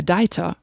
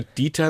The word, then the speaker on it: Dieter: Für sie ist er einfach der Deiter. – Weil sie Dieter einfach nicht aussprechen können. Ich sage immer Dieter like Peter, dann sagen sie aber trotzdem Deiter.